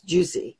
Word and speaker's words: Juizie: We're going to be talking about a new creation called Juicy, and it's spelled J-U-I-Z-I-E, but it's pronounced Juicy.